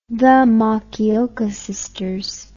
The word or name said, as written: The Makioka Sisters